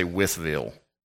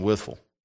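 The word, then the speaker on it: Wytheville: Just like you dare not say Withville. Nobody lives in Withville. We live in Withful, right?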